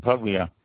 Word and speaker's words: Puglia: The Apulia region, Puglia, in Italian, they pronounce it completely different without the A and add a P to the beginning. Puglia